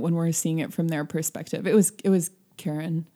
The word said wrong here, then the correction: Kieran: Is that how you pronounce her name? – Kieran. – Kieran.